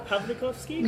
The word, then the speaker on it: Pawlikowski: Pawlikowski? – Yeah, Powell.